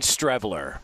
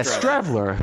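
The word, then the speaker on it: Streveler: I'm seeing Strevler. – Yeah, Strevler. Who wouldn't know that? Not Stevler. – The R is silent. – It's not Stevler.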